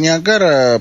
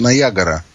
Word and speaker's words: Ниагара: У нас он называется Ниагара, потому что так он пишется, а на самом деле они все зовут Ниагара.